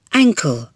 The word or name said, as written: ankle